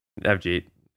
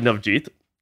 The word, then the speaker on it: Navjeet: I think my first name is pronounced different too, to be honest. I think it's like Navjeet, but everyone calls me Nav.